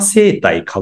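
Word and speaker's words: 製袋: ですので、 TSK というのも、本当富山製体株式会社、袋を製造すると書いて製体と読むんですけども、紙袋屋さんというところから、事業をスタートしてます。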